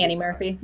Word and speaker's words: Annie Murphy: And I'm totally infatuated with Anna Murphy and everybody. – Annie Murphy. Not enough to learn her name. – I can never say her name right. Annie Murphy.